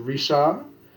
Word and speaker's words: Richard: or, yeah, do not pronounce the... – D? – The D, as it is Risha.